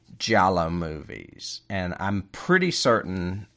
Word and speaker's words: Giallo: Gelato? No. – No. It's spelled G-I-A-L-L-O. Giallo. And it's the Italian word for yellow.